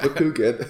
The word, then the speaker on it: Bakugan: Ben 10 Bakugan fight. You have to say it like that. Bakugan.